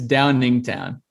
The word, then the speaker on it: Downingtown: So hoping he can knock some shots down from downtown, coming from Dowingtown, PA. – It's Downingtown.